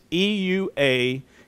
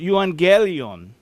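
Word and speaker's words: euangelion (εὐαγγέλιον): It's euangelion. And it's pronounced euangelion.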